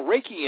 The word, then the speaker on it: Reiki: Hey, I hope I'm pronouncing this right, but you sent me some information in regards to, I believe it would be pronounced Reiki Energy Healing? – Yeah, Reiki Energy Healing.